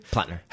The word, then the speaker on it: Plattner: Plattner.